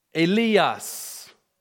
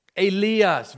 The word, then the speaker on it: Elias: Elijah in the Greek is pronounced Elias, Elias, very different from Eloi.